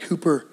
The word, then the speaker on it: Cowper: William Cooper, looks like Cowper, but it's pronounced Cooper, wrote a hymn called God Works in a Mysterious Way.